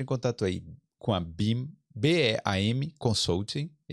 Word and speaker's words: Beam: BIM se escreve B-E-A-M.